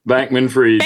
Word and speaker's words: Bankman-Fried: The other big news we've had this week is, and I'm trying to get the name right, is it Freed Bankman? – Nope, Bankman-Fried. – Bankman-Fried. Okay, thank you.